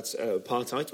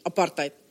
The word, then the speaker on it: apartheid: Like in your latest show, you talk about apartheid, which I'm pronouncing wrongly in my anglicised way. – Yeah, you made a point of this. Yeah, your dessert way. – And it's correctly pronounced. – Apartheid. – There we are.